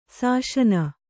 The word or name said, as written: ಶಾಸನ